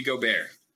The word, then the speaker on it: Gobert: Rudy Gobert.